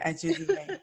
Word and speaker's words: Ajuzie: That's even prettier, Ajuzie.